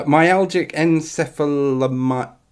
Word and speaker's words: myalgic encephalomyelitis: Uh, myalgic encephalomyelitis.